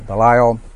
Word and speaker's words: Belial: Belial, I'm not for sure, but anyone got a better pronunciation of Belial? – Belial.